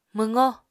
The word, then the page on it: mwqó: Apatani